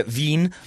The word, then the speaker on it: Wien: As Matt Herman pronounces it. Is it Wien? – I mean, that's what Matt Herman says. – I trust him. moved to Austria, Wien, on August 5th.